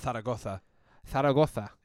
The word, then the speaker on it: Zaragoza: With Real Zaragoza. Is that how you say it? – Zaragoza.